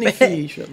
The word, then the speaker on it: Panificio: Y Panifish. – Panifish, exacto. No lo estoy pronunciando bien, obviamente. panificio No, es la que habla de italiano.